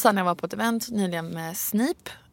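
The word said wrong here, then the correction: Sniph: Sniff, ursäkta. Sniff. Det stavas Snip och ett H på slutet. Men det uttalas ju Sniff.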